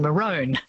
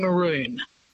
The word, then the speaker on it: maroon: So the original pronunciation, we know this quite clearly because we have people writing about pronunciation in the 16th and 17th centuries, which is when the word came in. It would have been maroon.